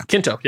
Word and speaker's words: Quinto: Quinto.